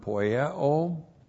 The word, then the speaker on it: poieo: And then we have the verb poieo. P-O-I-E long O.